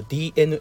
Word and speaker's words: DeNA: 白そうなんですよこれがねあのディーナじゃないごめんなさい本当にかっこ悪いねあの DENA 大文字の D 小文字の E 大文字の N 大文字の A でディーナって今言っちゃったけどあの DNA って読む読みますわ DNA ねあのゲームとかねスポーツのねこう事業をねこう中心に行っている DNA